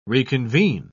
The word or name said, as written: reconvene